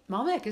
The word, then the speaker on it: Malbec: I really love Malbec. Am I saying that right? – Malbec is, yeah, absolutely.